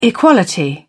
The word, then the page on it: equality: British English